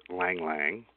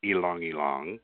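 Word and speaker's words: ylang-ylang: But actually, it's from the Latin name because it's mainly made with lang lang, which is actually pronounced ilong ilong.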